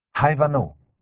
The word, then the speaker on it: Ivanhoe: Walter Scott, Ivanhoe, Rizzoli, 2009. Ho scoperto da adulto che Ivanhoe si pronuncia Ivanhoe e non Ivanhoe.